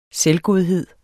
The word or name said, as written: selvgodhed